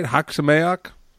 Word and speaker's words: Chag Sameach: But more importantly, we really just wanted to talk to you this week and just wish you Chag Sameach. Am I saying that right? Chag Sameach? – It's pretty good, yeah. You'd be understood.